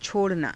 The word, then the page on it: cho_ranaa: hindi